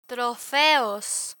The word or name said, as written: trofeos